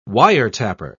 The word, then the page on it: wiretapper: British English